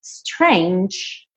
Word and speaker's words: strange: Strange, você não pronuncia a letra E, mas essa letra G, ela tem esse som de DJ, J, J, J, J, ok? Então, strange, strange.